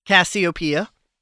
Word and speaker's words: Cassiopeia: The next song you guys played for us this fine evening is Cassiopeia. I pronounced that right? – You did.